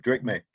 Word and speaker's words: Drake Maye: Yeah, Drake Maye.